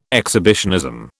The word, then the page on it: exhibitionism: British English